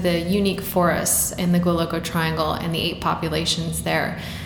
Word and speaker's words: Goualogo Triangle: Can you pronounce it for me? – Goualogo Triangle. – Goualogo Triangle. – That's right.